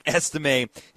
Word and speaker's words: Estime: And Audric Estime, I always love it from my time speaking French, Mitch, when I see a name with a little asterisk over the E, little French lesson for everybody that has an A sound at the end. So Audric Estime has been averaging 6 yards per carry over the past two games, 35 times, 210 yards, three touchdowns.